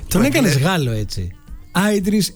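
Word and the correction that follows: Idris: Ελμπά. – Ίντρις Ελμπά, δηλαδή.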